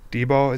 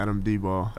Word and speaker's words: Dyball: D-Ball, is that how you pronounce it? – Yeah. – See, I'm ruining my reputation right now. – It's fine. – Yeah. I've only ever seen it, like, red. So, or I've only ever seen it, like, on paper. I've never heard anybody pronounce it. – Yeah, Adam D-Ball.